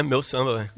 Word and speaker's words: Meu Samba: Alors ça s'écrit M-E-U. – Très bien, Meu Samba.